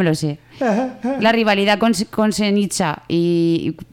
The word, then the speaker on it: Senija: Y la villa de al lado, que es Senija, que es un pueblo, voy a pronunciarlo como toca, Senija, que es un pueblo de 500 habitantes o así.